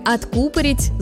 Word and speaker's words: откупорить: В слове «откупорить», «закупорить» ударение падает на второй слог, как, например, в медицинском термине «закупорка».